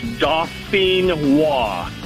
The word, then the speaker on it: Dauphinois: It's, like I said, it's French. It's Dauphinois.